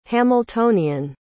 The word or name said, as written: hamiltonian